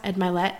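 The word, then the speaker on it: Mylett: Actually, sidebar, that is why I love, if you've never heard Ed Milet's podcast, Ed Milet, that's how you say it, Ed Milet's podcast, he is an incredible interviewer, like so good.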